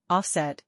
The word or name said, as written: Offset